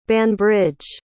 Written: banbridge

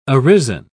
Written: arisen

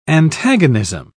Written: antagonism

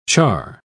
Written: charr

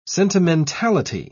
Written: sentimentality

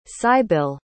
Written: cybille

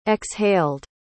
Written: exhaled

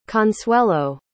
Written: consuelo

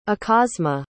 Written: akasma

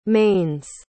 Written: mains

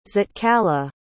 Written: zitkala

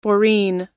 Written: boreen

